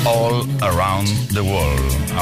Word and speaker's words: All Around the World: Lisa Stansfield también se nos pone de manifiesto ahora con Around the World. A ver si lo pronuncio bien. All Around the World.